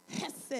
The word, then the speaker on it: chesed: What's interesting is that when we talk about this Constitution or this covenant, it is found in a Hebrew word called chesed. I had to say it like that. Chesed. It's spelled H-E-S-E-D. Some places you'll see it as C-H-E-S-E-D. But H-E-S-E-D.